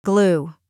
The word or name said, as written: glue